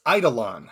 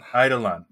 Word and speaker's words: Eidolon: It's Eidolon. – Eidolon.